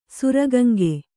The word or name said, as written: ಸುರಗಂಗೆ